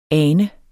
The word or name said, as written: ane